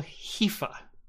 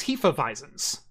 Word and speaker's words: Hefeweizen: Uh, when they were later corrected, it's no, it's Hefeweizen or a yeah.